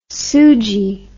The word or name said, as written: soogee